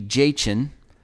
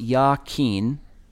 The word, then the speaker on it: Jachin: Uh, again, English, it looks like it should be Jachin, but in Hebrew, it's pronounced Yakin.